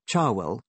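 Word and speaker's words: Cherwell: As it happens, the same student newspaper, Charwell, pronounced like the Oxford River after which it is named, once telephoned me when it was doing a survey of dons to see how cool we were.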